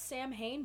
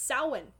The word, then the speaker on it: Samhain: It's spelled Samhain, but don't ever fucking call it that. It's Samhain.